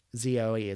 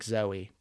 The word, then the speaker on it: Zoe: Z O E. It's like Zoe, but she pronounces it Zoe.